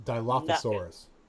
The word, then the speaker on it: Dilophosaurus: Dilophosaurus.